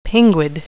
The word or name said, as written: pinguid